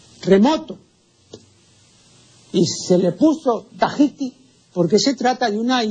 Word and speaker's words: Tahiti: Eh, estampa dos errores, porque no es tahitiano ni tampoco es Tahití. la pronunciación correcta en el nombre de esta isla es Tahiti.